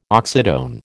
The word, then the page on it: oxytone: American English